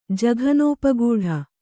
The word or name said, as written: ಜಘನೋಪಗೂಢ